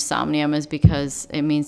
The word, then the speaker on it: Somnium: S-O-M-N-I-U-M. So it's Somnium. Like insomnia, but Somnium.